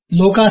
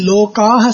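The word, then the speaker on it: Lokah: One more thing which one of the elders had told me is, when you say Loka Samastha, so what happens is actually it should be Lokaha Samastha. Lokaha means plural. But because when we say Loka Samastha, that Loka doesn't get clearly pronounced as Aha.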